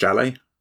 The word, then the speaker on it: Jale: I think it's pronounced Jale. I'm saying that like Raleigh, but with a J at the beginning.